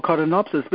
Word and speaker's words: codonopsis: And a thing called codonopsis, C-O-D-O-N-O-P-S-I-S, codonopsis.